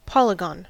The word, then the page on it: polygon: American English